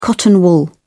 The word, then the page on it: cotton wool: British English